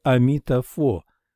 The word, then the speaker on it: Амита-фо: Он знает, что Авалокитешвара и Будда едины в качестве Амита-фо, произносится «фо», а Амита-будда идентичен первому.